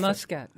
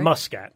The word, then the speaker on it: Muscat: We say often, Muscat, right? – Muscat, mm-hmm. Yes. Thank you. I'm pronouncing it with the Alsace folks in mind.